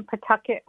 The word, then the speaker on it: Pawtucket: I was born in Pawtucket, Rhode Island. – Pawtucket.